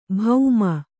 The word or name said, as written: ಭೌಮ